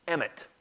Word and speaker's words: emet: The Bible contains a few words for truth, but the most important one, I think, is the Hebrew word emet, pronounced like Emmett County, spelled a little bit different, but it means stability, firmness, and certainty.